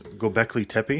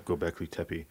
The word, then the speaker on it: Göbekli Tepe: Göbekli Tepe? Have you ever heard of that place? – No. – Okay, yeah, look up Göbekli Tepe.